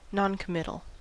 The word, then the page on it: noncommittal: American English